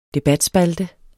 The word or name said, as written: debatspalte